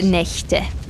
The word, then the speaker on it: Nächte: Vale, la, lo que pasa aquí es que la A cambia al umlaut, ¿no? Y eso hace que la CH ya no se pronuncie como una J, ¿no? Es die Nacht, como una J, sí. – Pero es die Nächte.